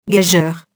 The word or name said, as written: gageure